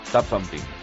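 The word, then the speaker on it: Tubthumping: Es algo así como Tum Thumbing. – Tap Thumping. – Mejor lo has dicho tú, sí.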